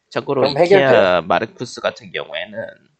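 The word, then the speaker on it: Markus: 마르쿠스네. 마르크스라고 읽으니까 씨 어디 붉은 형변 같잖아. 예, 마르쿠스.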